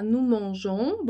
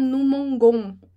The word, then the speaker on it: mangeons: Vai ter esse e ali, né? Pra gente não falar nous mangons. Então, esse e, ele ajuda na pronúncia.